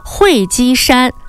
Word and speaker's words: 会稽: 多朋友知道啊，这个会稽啊，它是浙江绍兴的古称，指的是会稽山及周边的区域。那么呢，在这个时候哈，可能很多朋友呢都会觉得这个地名哈听起来好像很熟悉，但是呢又觉得有争议。比如说呢，作为地名的时候呢，像这个它的读音是变成什么呢？会稽山。